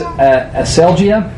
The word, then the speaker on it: aselgeia: Okay, the Greek word is aselgia. A-S-E-L-G-E-I-A is the anglicized pronunciation of that, or the anglicized of it.